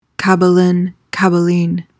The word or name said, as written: caballine